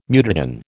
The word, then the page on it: mutagen: American English